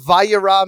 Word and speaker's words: Vayera: We're going to get into the story, but what does Vayera even mean? – First of all, the Hebrew pronunciation was excellent. It's Parshat Vayera, as we say it here in Israel.